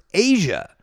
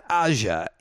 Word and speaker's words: Aja: Steely Dan with Josie from the album entitled AJA, which actually is pronounced Asia. It's not Aja, it's Asia. So there you go.